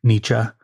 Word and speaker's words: Nietzsche: There was one that was Nietzsche for Beginners, or if you want to say his name correctly, Nietzsche. Although every time you say Nietzsche, people look at you like you're crazy because they don't know what Nietzsche is, but they know what Nietzsche is.